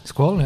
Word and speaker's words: Squall: Wie spricht man es eigentlich aus? Squall? – Squall, ja.